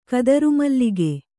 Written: ಕದರುಮಲ್ಲಿಗೆ

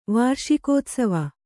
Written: ವಾರ್ಷಿಕೋತ್ಸವ

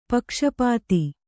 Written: ಪಕ್ಷಪಾತಿ

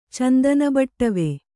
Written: ಚಂದನಬಟ್ಟವೆ

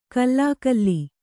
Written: ಖುಲಿಖುಲಿ

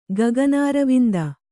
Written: ಗಗನಾರವಿಂದ

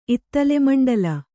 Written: ಇತ್ತಲೆಮಂಡಲ

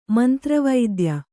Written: ಮಂತ್ರವೈದ್ಯ

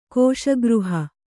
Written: ಕೋಶಗೃಹ

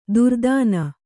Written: ದೊರೆತನ

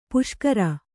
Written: ಪುಷ್ಕರಿ

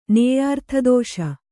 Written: ನೇಯಾರ್ಥದೋಷ

